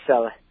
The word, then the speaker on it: Celle: called Celle. And pronounced Celle.